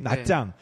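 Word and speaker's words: Nha Trang: 베트남을 만일에 한반도에 비유하면 한 포항쯤 되는 위치에 있는 그 해안 도시 나짱. 네. 그러니까 나트랑이라고 많이 알고 있는데요. TR 발음이 사실은 그 쌍지읒 발음이 납니다.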